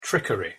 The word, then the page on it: trickery: English